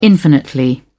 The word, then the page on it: infinitely: British English